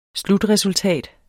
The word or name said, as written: slutresultat